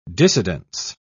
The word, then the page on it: dissidence: British English